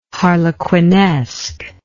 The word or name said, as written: harlequinesque